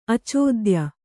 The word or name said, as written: ಅಚೋದ್ಯ